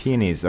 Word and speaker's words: peonies: No, the stress is in a different place. It's at the beginning. Peonies. – Peonies, okay. – And you kind of almost barely say the O.